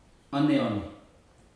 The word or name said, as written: oneone